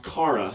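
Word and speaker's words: chara (χαρά): And the word for joy in Greek is a word, or is pronounced chara, C-H-A-R-A.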